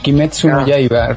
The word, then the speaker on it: Kimetsu no Yaiba: Ese, eh, ah, el último que, que pronunciaste no es tan difícil. Kimetsu no Yaiba.